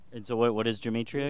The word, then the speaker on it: gematria: It's pronounced gematria, which we'll get to in a minute.